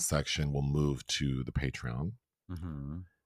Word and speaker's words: Patreon: We're going to start a Patreon or patron. I'm not quite sure how to pronounce it. Uh, I didn't bother to look it up. – I but it's Patreon. But but I've I've already started it.